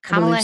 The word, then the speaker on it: Kamala: Kamala Harris.